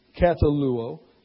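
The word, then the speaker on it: kat-al-u-o (καταλύω): So first of all, you have the original letters in Greek, kataluo, and then you have the transliteration into English, kataluo, and then you have the pronunciation, kat-al-u-o.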